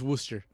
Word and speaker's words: Worcester: Worcester, like Worcester sauce? – I think they, yeah. Like or is it Worcester? – It's Worcester. – Worcester. – They pronounce it Worcester.